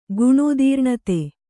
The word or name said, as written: ಗುಣೋದೀರ್ಣತೆ